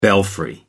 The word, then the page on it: belfry: American English